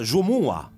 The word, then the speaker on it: Jumua: Senti, come si pronuncia? – Jumua, con l'accento sull'A.